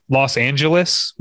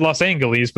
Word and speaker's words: Los Angeles: Like everyone saying Los Angeles with a G, like a soft G, didn't really happen until the 70s.